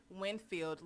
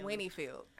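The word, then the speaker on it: Winnfield: I say her hometown name wrong. – Jack it up. – What is it? – Winfield, Louisiana. – How you messed it up? – What you be saying? – Winniefield. – I say Winniefield.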